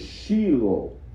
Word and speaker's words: Shiloh: Well, we say Shiloh. The correct pronunciation is Shiloh.